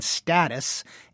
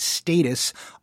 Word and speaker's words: status: The pronunciation status is the older pronunciation, and it remains the one most common in British English.